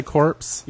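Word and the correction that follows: corps: Yes. Because corpse is the thing that lays in the morgue. – It says corpse. – Where? – Right there. – Yeah, that's core. That's pronounced core.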